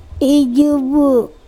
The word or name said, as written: ijubu